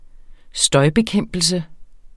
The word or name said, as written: støjbekæmpelse